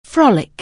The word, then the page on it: frolic: anglais